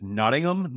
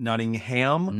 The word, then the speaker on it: Nottingham: Like a British, it's Nottingham, not Nottingham.